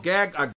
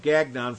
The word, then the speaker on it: Gagnon: Now, they were all called Gagnon is the pronunciation of his name in French, but we often call it Gagnon for the non-French-speaking people.